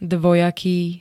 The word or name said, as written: dvojaký